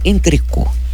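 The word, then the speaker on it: entrecôte: A pronúncia do corte da carne é entrecô.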